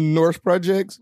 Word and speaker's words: Norse Projects: Norse Projects. – Ooh. – Is that how you pronounce it? – Yeah, yes. That is absolutely correct.